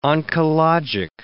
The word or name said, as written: oncologic